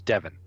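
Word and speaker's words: Devine: It's written Divine, and I thought it was Divine, but every announcer I heard on tape says Devin. So we'll call him Devin.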